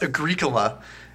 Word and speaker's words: Agricola: It's Agricola. It's like the pronunciation. – It's like the cough drop with an A and G in front of it. That's how I remember.